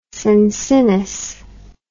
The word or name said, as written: cincinnus